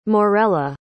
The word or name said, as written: morella